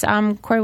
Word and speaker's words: Corry: It's Corry Weller, off-road racer. You know, I have a Twitter. It's Corry Weller.